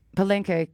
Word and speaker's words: Palenque: The highlight of this was going to the Pequen. Am I saying this right? Palenque. God, I'm so bad at this. I'm really sorry. My Spanish is very bad. The Palenque that we went to was our driver / host / mezcaler.